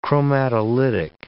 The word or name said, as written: chromatolytic